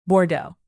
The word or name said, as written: bordeaux